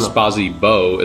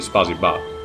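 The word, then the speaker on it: Spazi Ba (спасибо): Most of them are like, the thank you in Russian, which is spelled Spazi Bo, is actually Spazi Ba.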